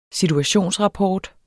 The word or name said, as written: situationsrapport